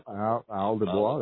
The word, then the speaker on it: Isle du Bois: Yep, Isle du Bois.